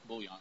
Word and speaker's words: bullion: Do you say bull? Bullion? – Bull, like B-U-L-L is the way I pronounce it. – Bullion? – Bullion.